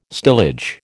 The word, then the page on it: stillage: American English